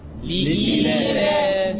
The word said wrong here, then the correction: لإيلاف: لا لا، أنت قلت لي في أصل همزة وفي ياء، أنا عايز النطق الصحيح. – ليلاس. – لا ليلاس. – ها؟ – لإيلاف. – لإيلاف. – اتفضل. – لإيلاف. – اسمعها بقى. لإيلاف لإيلاف قريش هكذا.